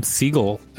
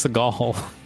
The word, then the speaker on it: Seagal: Why did you start calling him Siegel? It's Seagal. – But look at how his name is spelled. – Yeah, but we've been saying Steven Seagal the whole time, and then in the last sentence you changed his pronunciation to Siegel.